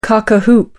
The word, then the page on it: cock-a-hoop: American English